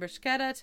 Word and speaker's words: bruschetta: I like how you pronounce bruschetta. – What do I say? – Say it. – Bruschetta. – Okay, you said it right that time. – How did I say it before? – you get a K in there somehow.